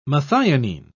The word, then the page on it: methionine: British English